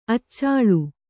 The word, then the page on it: ಅಚಲೆ: kannada